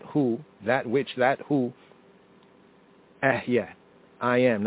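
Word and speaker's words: Ehye (אהיה): Now some latter-day Hebrews and Israelites, they mispronounce it. They call it correct, but it's really incorrect if we study Hebrew more comprehensively, right? But it's baby Hebrew, all the same. They will say it's Ahya, Ahya.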